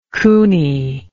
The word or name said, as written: coony